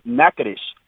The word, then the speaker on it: Natchitoches: It's pronounced, I'm in both of those towns at least once a week. So it's Nacogdoches, Texas, which run off of Highway 59, and it's Natchitoches, Louisiana, which run off of 49.